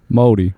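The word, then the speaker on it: Māori: How do you say it for real? – Maori.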